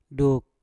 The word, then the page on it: duk: Mpur